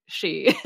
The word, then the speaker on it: sidhe: So the she part in Irish is spelled S-I-D-H-E. Like I would never look at that and think she.